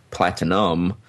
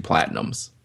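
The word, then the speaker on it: Platignum: Therefore, the owners added a silent G to Platinum to bypass the law. However, there are regional differences in terms of pronunciation of platinum, and in the North, the G was usually stated.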